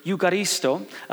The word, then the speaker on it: eucharisto: The word thankfulness is the Greek word eucharisto. Uh, if you were raised in the Catholic tradition like I was, uh, it sounds like the word Eucharist, which is the word that we use for communion.